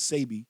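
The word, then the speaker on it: Sebi: I even started drinking the Dr. CBT, Dr. CBT, and I just watched his family on The Breakfast Club, and they did say the correct pronunciation is Sabi,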